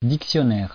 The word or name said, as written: dictionnaire